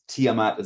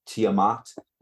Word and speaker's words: Tiamat: Um, okay, so Tiamat mostly pronounced Tiamat as I say it. Apparently, some people in the world pronounce it Tiamat, like how some people say taco. Do what you want, but you're wrong.